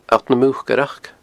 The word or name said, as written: alltnamucaireachd